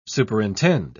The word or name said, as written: superintend